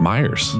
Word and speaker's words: Myers: How do you say your last name, Ryan? – Myers. – Myers, yeah. Okay. – Straightforward. Yeah. M-Y-E-R-S. We don't mess around with an extra E.